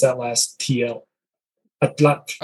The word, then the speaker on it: atlatl: And then atlat is the original Nahuatl pronunciation.